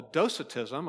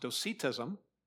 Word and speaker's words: docetism: In fact, one of the errors in church history that had to be addressed is called docetism. I've also heard it pronounced docetism.